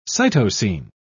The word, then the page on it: cytosine: British English